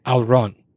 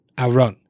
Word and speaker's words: Alraune: This is a German word, A-L-R-A-U-N-E, Alron. Alron. There's like a H in there somewhere. I don't know how to actually say it, but it is called Alron.